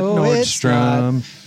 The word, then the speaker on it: Nordstrom: It's that most people mispronounce it and add an S to the end of the name. – Oh, is that right? Because there's no S at the end of Nordstrom.